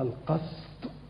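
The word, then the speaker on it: القَسط: احنا قلنا القسط ده يعني معناه العدل، وقلنا ان الماده دي اللي هي القاف والسين والطاء مره تنطقها القسط بكسر القاف ومره تنطقها القسط بفتح القاف. القسط بالكسر هو العدل، والقسط بالفتح هو الظلم.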